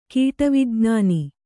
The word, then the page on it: ಕೀಟವಿಜ್ಞಾನ: kīṭa vijñāna